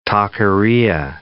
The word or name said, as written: taqueria